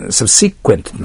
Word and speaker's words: subsequent: Subsequent となりますね。これはそのように書いてありますけども、 subsequent という発音した方がいいですね。